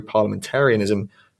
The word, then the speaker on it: parliamentarianism: the crisis of contemporary parliamentarianism, is that how you say that? The crisis of contemporary parliamentarism, how do you say that word? – Parliamentarianism. – Ah, okay. The crisis of contemporary parliamentarianism would not be overcome in the least.